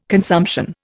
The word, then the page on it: consumption: Indian